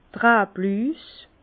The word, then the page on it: d'r Rablüs: Bas Rhin; Herrlisheim